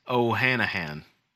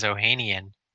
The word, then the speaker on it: Ohanian: So, I noticed down here toward the bottom of the article, it mentions Reddit co-founder or Reddit founder Alexis Ohanian. Is that how you pronounce his name? – Ohanahan. – Nah, that says Ohanian.